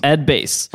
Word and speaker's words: Bass: Ed Bass.